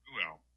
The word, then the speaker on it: Reuel: Reuel. Reuel. – So I was pronouncing it wrong. It's Reuel. Reuel.